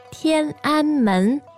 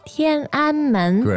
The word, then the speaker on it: 天安门: 天安门. 天安门. – Great. 天安门. Now, as we all know in English, it's 天安门. So the pronunciation with the tones here are quite important.